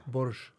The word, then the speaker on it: Borsch: Eh, brolsch, eller hur uttalar man det? – Borsch. Borsch. Eh, den är mycket populär att äta i Lettland också, precis ja.